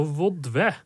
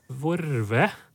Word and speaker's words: vodve: Uh, så vodve. Og da er det ei uttaleform her med r, vorve, som er belagt nettopp fra lom og sjåk.